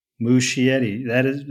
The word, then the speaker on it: Muschietti: Are you going to take a guess how to say his last name? – Muschietti? – Muschietti. That's correct.